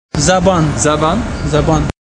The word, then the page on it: Zabaan: Farsi